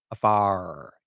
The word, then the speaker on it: Afar: His name was Dr. Michael Afar. Afar. You had to say it like that.